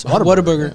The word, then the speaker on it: Whataburger: Pulling me. Whataburger? It's Whataburger. – Yeah. Body Armor Burger. – Tomato, tomato. Whataburger, Whataburger.